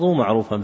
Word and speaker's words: معروفا: وأشار الناشر إلى أنه في الهندية نسخة معروفا، فهي وإن كتبت معروف تلفظ معروفا، فهذه لغة معروفة.